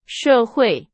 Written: 社会

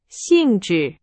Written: 性质

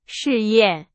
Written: 试验

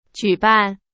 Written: 举办

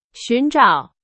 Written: 寻找

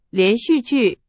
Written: 连续剧

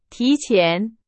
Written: 提前

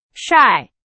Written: 晒

